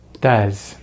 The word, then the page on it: does: American English